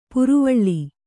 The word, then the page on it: ಪರಿವೇಲ: kannada